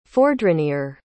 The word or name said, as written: fourdrinier